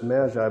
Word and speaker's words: Magi: I wouldn't either, except that I was working on this subject of the Magi. As you know, we're commonly understood as Magi, but it's actually Magi. That's the way it's pronounced, the wise men.